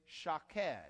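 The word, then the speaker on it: shaked (שָׁקֵד): Now, almond in Hebrew is shaked. Shaked. It's the shin, the kof, and the dalet. Three Hebrew letters. The vowel sound is an A, shaked.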